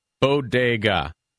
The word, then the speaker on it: Bodega: Yeah, true. Bodega. There's a good pronunciation guide in Half-Baked if you need it. – Did I say Bodego? – You did say Bodego.